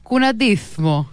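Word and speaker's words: cuñadismo: Δεν φαίνεται για ισπανικό έτσι όπως το πες. Θέλει θου. – Θου, ωραία. Κουναντίθμο.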